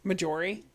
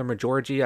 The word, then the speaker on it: Marjorie: Marjorie? Yeah, it's Marjorie. – I don't know her name. I'll admit it. I've never said her name right. I've called her Majorgie. I've called her her Marjorie. I've called her, like, every name under the sun except her actual name.